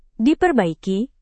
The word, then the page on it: diperbaiki: Indonesian